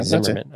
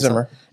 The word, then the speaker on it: Zimmer: I think I said his name right. I don't know why I want to say Zimmerman. – It's Zimmer. – I always want to say Zimmerman, and I'm like, no, it's not Zimmerman. Anyways, I'm a big fan of his, so I like his stuff.